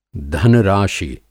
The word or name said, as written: धन-राशि